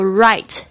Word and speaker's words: write: So write W-R-I-T-E，特别注意一下这个 W 是不发音的，很多 W-R 的这个开头的字呢通常都是 W 不发音，